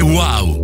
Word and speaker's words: UAU: Si scrive UAU, si legge wow.